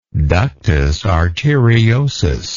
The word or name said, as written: ductus arteriosis